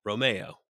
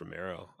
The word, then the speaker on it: Alfa Romeo: Romeo.